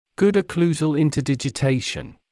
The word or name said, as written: good occlusal interdigitation